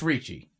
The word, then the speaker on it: Ricci: It's Richie. R-I-C-C-I. – Yeah, why would that be an E sound? – That's how you pronounce it. – This is Italian.